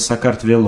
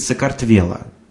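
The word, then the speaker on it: сакартвело (საქართველო): И оно, оно читается так, оно читается сакартвело Вело. А если вы не заметили, то здесь я пытаюсь равномерно расставить ударение. А если русский говорит человек, он обычно говорит «сокартвело». То есть он четко ставит ударение где-нибудь на букве «е» э, по привычке.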